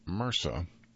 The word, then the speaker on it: MRSA: Pronounced MRSA, the acronym stands for Methicillin-Resistant Staphylococcus aureus.